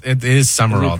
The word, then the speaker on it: Sumrall: Is that how you say it? He's new. He became part of Sumrall. – I know, it's weird. It is Sumrall, though. – Is it pronounced Sumrall? – Yeah. – I feel like there's a letter missing. – It is. It's not spelled right, but that's how it's pronounced.